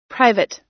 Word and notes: private: American English

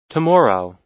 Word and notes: tomorrow: American English

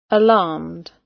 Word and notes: alarmed: British English

